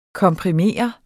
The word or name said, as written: komprimere